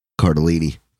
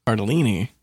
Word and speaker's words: Cardellini: Linda Cardinelli. Cardinelli. How do you say that? – Cardellini. – Cardellini.